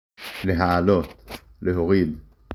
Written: להעלות

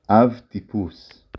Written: אבטיפוס

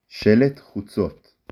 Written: שלט

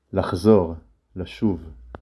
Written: לשוב

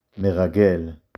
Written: מרגל

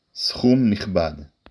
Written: סכום

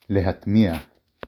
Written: לְהַטְמִיעַ

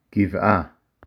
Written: גבעה

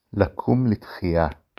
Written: לקום לתחייה

